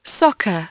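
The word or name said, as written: soccer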